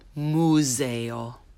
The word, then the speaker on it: museo: You might be tempted to have the U sound in there that you're used to in English. So I will say it the mispronounced way first, and then the correct way, and leave a pause for you to repeat. So instead of museo, say museo. Museo.